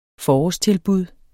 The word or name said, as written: forårstilbud